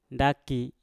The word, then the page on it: ndaki: Mpur